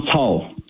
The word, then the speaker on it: Cao: Is it Pastor Cao? – Yeah, Pastor John Cao. – Thank you. Can you tell me his background? What is the story? – What happened to Pastor John Cao is last name is spelled as C-A-O, C for Charlie, C-A-O.